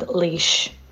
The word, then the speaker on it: Laois: I need to correct you on your pronunciations. It's Sligo, Mayo, and Galway. Then Armagh, Tyrone, and Offaly. And then Leitrim, Donegal, and Laois.